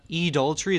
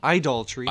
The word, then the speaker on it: idolatry: You actually said it wrong. It's not Edaltry, it's Idaltry.